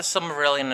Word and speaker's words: Silmarillion: And the Silmarillion, did I say it right? – Yeah.